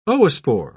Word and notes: oospore: British English

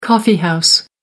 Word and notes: coffee house: American English

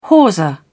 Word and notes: hawser: British English

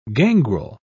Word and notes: gangrel: British English

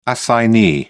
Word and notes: assignee: British English

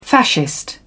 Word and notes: fascist: British English

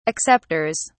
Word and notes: acceptors: British English

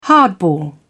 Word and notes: hardball: British English

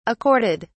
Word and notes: accorded: British English

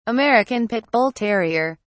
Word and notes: american pit bull terrier: British English

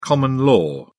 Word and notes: common-law: British English

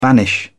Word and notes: banish: British English

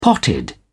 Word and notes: potted: British English

